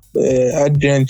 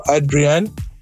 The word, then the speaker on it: Adrian: Yeah, so basically, I see myself as an arts administrator in terms of, my name is Manka Adrian Chipindi. I always joke that when you go to my village and ask for Adrian or Adrian, I don't even know the correct pronunciation, it will take you like 5 minutes to find me.